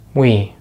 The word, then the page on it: we: American English